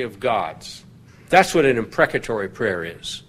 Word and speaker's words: imprecatory: I'll spell it. I-M-P-R-E-C, imprec, A-T-O-R-Y, atory, imprecatory.